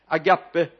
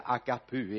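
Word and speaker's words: agapu (ἀγάπη): skrivit ner agape lika med ska man uttala det rätt så är det agapu egentligen men vi har ju lärt oss att det heter agape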